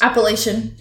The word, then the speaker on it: Appalachian: Is it okay. Appalachian Mountains? – Appalachian, yeah.